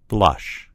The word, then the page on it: blush: American English